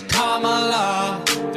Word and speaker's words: Kamala: It's not Kamala, no, her name is Kamala. Emphasize the first syllable, it's Kamala.